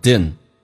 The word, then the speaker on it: didn't: Didn't 这个单词。在口语化当中经常加快速度 D 跟 N 组合，经常 D 不发音就发成 Din, Din.